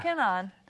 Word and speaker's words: Kanaan: Oh, our Be the One driver, Tony Kanaan? – Tony Kanaan.